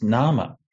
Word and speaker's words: nama: It's actually etymologically the same as the English word name, which until a few hundred years ago in England was actually just pronounced nama. So that shift from the pronunciation of nama to name happened only recently.